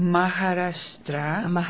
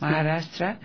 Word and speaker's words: Maharashtra: Maharashtra. – Sí. – Maharashtra.